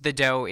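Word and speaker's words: Dø: It is pronounced The Dough. It is spelled D O, and the O has kind of a slash through it.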